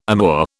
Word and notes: amour: British English

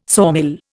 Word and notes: sawmill: Indian

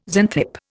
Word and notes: Xanthippe: Indian

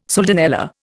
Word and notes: soldanella: Indian

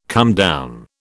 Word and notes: comedown: British English

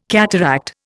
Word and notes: cataract: Indian

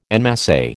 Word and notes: en masse: American English

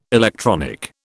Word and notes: electronic: British English

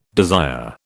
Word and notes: desire: British English

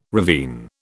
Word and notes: ravine: British English

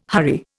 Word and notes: hurry: Indian

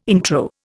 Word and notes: intro: Indian

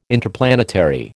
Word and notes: Interplanetary: American English

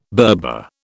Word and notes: Berber: British English

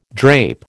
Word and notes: drape: American English

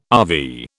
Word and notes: RV: British English

